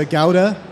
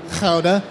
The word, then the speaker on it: Gouda: Like Sebi had said, it's a Gouda or a Gouda, however you want to pronounce it, Gouda, whatever.